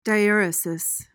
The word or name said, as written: diaeresis